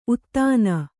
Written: ಉದಾನ

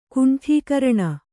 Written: ಗಂಡುಗರಣೆ

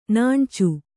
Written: ನಾಣ್ಚು

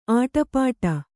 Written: ಅಡೆಪಟ್ಟಿ

